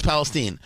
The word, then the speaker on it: Palestine: This is what one of the people there in East Palestine, it's spelled Palestine, but it's pronounced Palestine, had to say about what the railroad offered her.